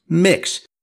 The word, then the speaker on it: Mx: In our research, we stumbled on a New York Times article from 2015 that describes social media discussions about MX period, which is pronounced mix,